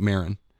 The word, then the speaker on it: Maron: Well, let's jump into here first with some of the history of with St. Maron, who...